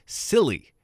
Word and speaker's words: Scilly: That is a mispronunciation. It is Scilly. There are seriously some islands off the coast of England, S-C-I-L-L-Y, Scilly, the Isles of Scilly.